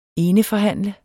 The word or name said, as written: eneforhandler